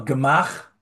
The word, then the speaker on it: gemach: It's spelled in English, Gemach, gemach, but it's not a real word. It's an acronym. It stands for Gemilut Chasadim. It's an acronym, Gimel Mem Chet, gemach, but it's not a real word.